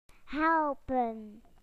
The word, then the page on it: to help: British English